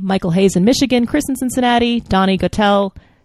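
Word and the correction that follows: Gettel: I believe it's Donnie Gettle. It rhymes with kettle. – Yeah, that's right. – Donnie Gettle rhymes with kettle.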